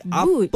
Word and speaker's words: but: Okey, perkataan kita untuk hari ini adalah but. Ejaan dia B-U-T. Boot.